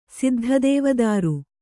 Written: ಸಿದ್ಧದೇವದಾರು

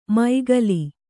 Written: ಮೈಗಲಿ

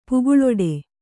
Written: ಪೀಕಲಾಟ

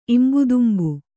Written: ಇಂಬುದುಂಬು

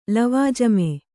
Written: ಲವಾಜಮ್ಮು

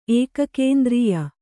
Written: ಏಕಕೇಂದ್ರೀಯ

